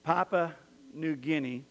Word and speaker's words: Papua New Guinea: This man, Dean Sherman, was in Papua New Guinea.